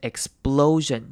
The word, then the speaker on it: explosion: 好，来看 explosion 这个字，首先注意的是它中间的 p 呢，我们会念成 b 的声音，而不是 p，所以我们念成 explosion，念成 b 的声音，explosion， explosion。